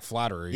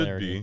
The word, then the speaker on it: Flaherty: But is the MLB pronunciation, is it actually Flaherty? Is that how they have it in the book off the top of your memory? – I believe so. It should Flaherty. Be because that's the correct way to announce it.